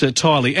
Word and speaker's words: Tiley: Wally from Blackburn South, it's pronounced Tiley. Absolutely, yes. I don't know why he's called Craig Tilley by anyone, but it is Craig Tiley.